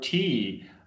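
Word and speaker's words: te (茶): tea. The tea is either tea or chai in most of the world, and it's tea because a southern Chinese language, Minnan language, the word for tea is pronounced te.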